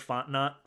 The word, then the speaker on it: Fontenot: Mike Fontenot. – Yeah, it's Louisiana, so you don't pronounce that consonant.